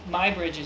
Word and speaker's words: Muybridge: It, but does one say in English Muybridge or Muybridge? – Muybridge is usually how it's said, but Muybridge is not wrong.